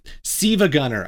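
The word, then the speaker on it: SiIvaGunner: Actually, Siva Gunner, I think, is how you're supposed to pronounce it. – Siva because Gunner. – Apparently, and this shocked me this week, I didn't know this, like, the L is not an L, it's an I.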